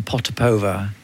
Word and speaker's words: Potapova: She is happy with the pronunciation Potapova. I think in Russia they would say it slightly differently, but they'd also...